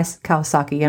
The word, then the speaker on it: Kawasaki: Kawas, yeah, Guy Kawasaki.